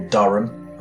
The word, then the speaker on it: Durham: She's from Durham University, or Durham. I keep saying it wrong.